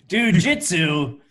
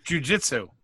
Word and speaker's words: jiu-jitsu: You butchered whatever you tried to say there. Jiu-jitsu. – Jiu-jitsu. – Yeah, there you go.